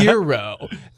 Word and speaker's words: gyro: If it's a gyro group, just say gyro. – Don't be the one person who's like, well, actually, it's pronounced euro.